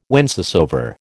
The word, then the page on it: whencesoever: American English